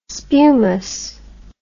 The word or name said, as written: spumous